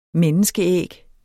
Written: menneskeæg

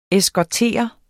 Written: eskortere

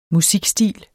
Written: musikstil

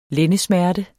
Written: lændesmerte

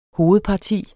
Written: hovedparti

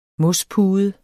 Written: mospude

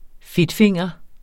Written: fedtfinger

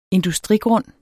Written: industrigrund

